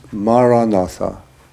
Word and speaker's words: Maranatha: So the word I would recommend is the word Maranatha. It's an ancient Christian prayer in the language that Jesus spoke. It means come Lord, and the word is Maranatha, four syllables, Ma-ra-na-tha.